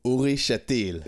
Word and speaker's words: Uri Shatil: So my name is Ori Shatil.